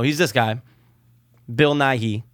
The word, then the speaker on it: Nighy: Bill, I think it's pronounced Nighy. It's N-I-G-H-Y.